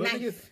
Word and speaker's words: knife: K, knight. Like a knight, like a fighting, like a shining armor. Nigga, as a kid, I had so much trouble learning. – Knife. It's the silent letters.